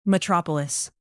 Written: metropolis

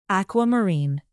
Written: aquamarine

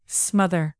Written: smother

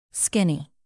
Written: skinny

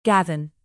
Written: gavin

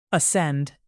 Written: ascend

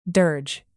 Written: dirge

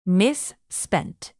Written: misspent

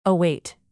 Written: await

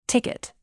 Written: ticket

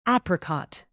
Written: apricot

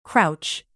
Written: crouch

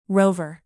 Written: rover

